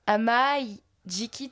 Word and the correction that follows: Amajiki: Amajiki, hein, je crois que c'est Amajiki.